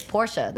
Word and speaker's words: Porsche: Naming myself Porsche because you know, I don't know if a lot of people know, but Porsche is not Porsche. It's Porsche. That's how you right.